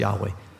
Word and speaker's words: Yahweh: It's believed to be pronounced Yahweh. You can't pronounce that word.